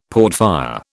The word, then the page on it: portfire: British English